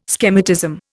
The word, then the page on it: schematism: Indian